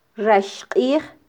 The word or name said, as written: raxqij